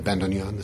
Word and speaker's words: bandoneon: I'm from Denver, and I make bows for string instruments is my main occupation, but I'm here playing the bandoneon this weekend.